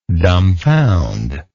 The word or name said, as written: dumbfounderment